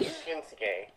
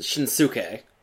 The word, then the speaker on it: Shinsuke: Like, Nakamura Shinsuke, which is how you pronounce that. – Right. – And that's when you have the U in it, Nakamura. – Yeah, but it's in Mora, which you do say the U there. – Yeah, you say Mora or some other prefect that you do say the U, you do say that. – Yeah, but some people pronounce it Shinsuke. – Which I've actually heard that. – That's wrong. – That's totally wrong.